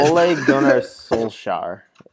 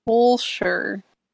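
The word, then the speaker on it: Ole Gunnar Solskjær: Is it Ol or Ole Gunnar Solskjaer? How do you pronounce this guy? – Ole Gunnar Solskjaer. I think is how they Solskjaer. – Right. – Solskjaer. Ole! Yeah, that's right.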